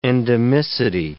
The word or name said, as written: endemicity